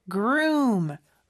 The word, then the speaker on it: groom: Groom. Room이 더 크게 들려야 돼요. 그 여기서 O가 두개 있는데 그냥 우우 소리가 아니라 으우 소리예요. 그래서 groom 이렇게 발음이 된다는 것도 기억해 주세요.